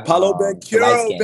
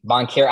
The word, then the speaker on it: Banchero: The guy, Paolo Banchero, baby. – Banchero. I was like, I know Banchero, his last name is not pronounced baby. How it's spelled. Banchero. Okay, that's how you say it.